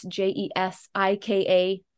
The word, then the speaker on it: Jesika: My name's a little different, J E S I K A.